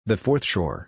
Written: the fourth shore

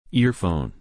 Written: earphone

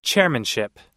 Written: chairmanship

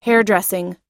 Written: hairdressing

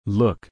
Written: look

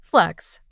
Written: flex

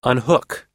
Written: unhook

